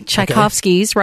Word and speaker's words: Tchaikovsky: Okay, Tchaikovsky's, right? – Yes, Tchaikovsky. – Did I pronounce it correctly? – You did.